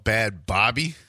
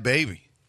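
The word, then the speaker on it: Bhad Bhabie: So I used to think it was called Bad Bobby because it was B-A-H-B-I. But then someone corrected me and said, no, she pronounced it baby.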